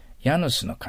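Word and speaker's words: Janus: January の J はね、ラテン語というのかな、ギリシャ語というのかな、の発音はヤなんですってね。ヤヌスの神。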